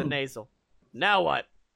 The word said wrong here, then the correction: nacelle: Hold on, it's it's pronounced nacelle.